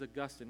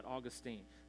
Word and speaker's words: Augustine: The way it should be pronounced is Augustine, not Augustine.